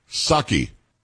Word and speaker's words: Psaki: Psaki. – Psaki? – It's not Psaki. It was just Psaki. You didn't pronounce the P.